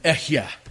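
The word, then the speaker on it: Ehyeh: Yahweh, Echyeh, is the literal Hebrew pronunciation in this instance.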